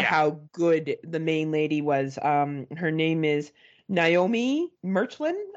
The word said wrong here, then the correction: Merlant: Naomi Merlant.